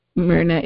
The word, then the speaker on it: Myrna: Myrna is spelled M-Y-R-N-A.